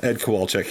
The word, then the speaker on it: Ed Kowalczyk: Yes, Ed Kowalczyk.